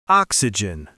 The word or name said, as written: oxygen